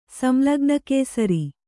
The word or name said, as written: ಸಂಲಗ್ನಕೇಸರಿ